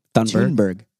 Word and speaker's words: Thunberg: Thunberg. – Greta Thunberg. Oh, Thunberg? – I've heard Thunberg, but I think it's Thunberg.